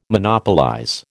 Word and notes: Monopolize: American English

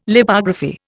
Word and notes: Lipography: Indian

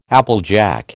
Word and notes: applejack: American English

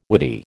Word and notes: whity: American English

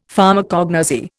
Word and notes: Pharmacognosy: Indian

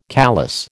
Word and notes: callous: American English

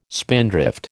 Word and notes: spindrift: American English

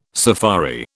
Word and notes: safari: British English

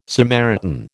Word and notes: Samaritan: American English

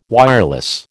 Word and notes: wireless: American English

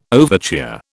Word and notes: overture: British English

